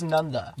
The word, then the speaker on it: Nanda: And then the, like T-H-E. – Yeah. – It's like nonetheless without the less. – Nanda. So just Nanda? – So it's Nanda.